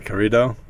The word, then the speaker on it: corrido: Am I pronouncing it right again? Corrido? Corrido? Corrido. – Corrido. That's close enough.